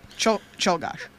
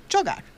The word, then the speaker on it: Czolgosz: Kulgosh. – Cholgosh. But it's spelled C-Z-O-L-G-O-S-Z. Cholgosh. – Cholgosh. She even wrote the pronunciation. I still got it wrong.